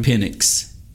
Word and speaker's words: Penix: How do you say his last name? – Penix. – Thanks for the clarification.